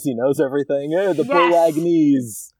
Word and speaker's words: Bolognese: a seven-course Bolognese. Oh. – At one point he says he makes a Bolognese feast. Fuck you, Flash. – I thought he like pronounced the G and everything too.